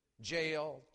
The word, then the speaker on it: Jael: It rhymes. Jael took a nail.